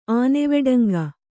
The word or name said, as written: ಆನೆವೆಡಂಗ